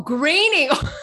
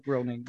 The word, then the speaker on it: Groening: Is it Groening or Grenning? – They pronounce it Groening. – Oh, graining. – Yeah, but I was like groaning. – Thank you. I thought graining, this whole time groaning. – Me too. – Graining.